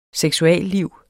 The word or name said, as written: SEKSUALLIV